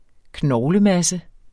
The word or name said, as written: knoglemasse